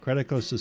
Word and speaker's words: Creticos: Creticos is spelled C-R-E-T-I-C-O-S.